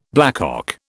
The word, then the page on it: blackcock: British English